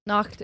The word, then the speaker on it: Nacht: A good costume worn during Krampus Nacht can cost... I'm butchering these words and I'm sorry, they're German. – Nacht is correct.